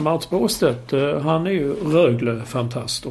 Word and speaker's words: Rögle: Alltså, Rögle. Nej, så du ska på tung betoning på första som Rögle.